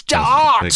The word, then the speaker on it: Da'ath: And everyone says Daniel Death. He goes, it's Dart.